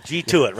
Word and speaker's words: G2IT: G2IT, right? – Yeah, G2IT. – Like, get to it.